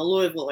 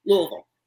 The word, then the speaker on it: Louisville: Did that in the Louisville area. Louisville, I said it wrong. I'm from there. I should know. Louisville area.